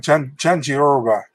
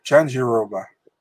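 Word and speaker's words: Jandiroba: Janjidoba, even though, how do you say it? How do you say it? – Just like Janjiroba. Janjiroba.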